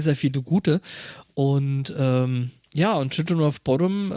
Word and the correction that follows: Bodom: äh, oder Bodom, wie es, äh, glaube ich, richtig ausgesprochen wird, äh, stechen da immer noch mal so ein bisschen raus.